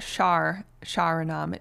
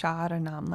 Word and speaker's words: Sharanam: Because it's not Sharanam. It's Sharanam. Like a soft R. Almost like Shadanam with a D.